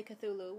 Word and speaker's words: Cthulhu: It's like some people say Cthulhu, which is correct.